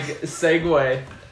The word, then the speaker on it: segue: I always thought it was like spelled like Segway, the little scooter. It's actually spelled S-E-G-U-E.